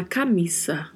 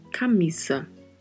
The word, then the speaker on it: camisa: A diferença é a pronúncia porque a letra A é sempre pronunciada de forma mais aberta e o S tem mesmo som de S, não é como no português que nós falamos camisa. Em espanhol se fala camisa, camisa.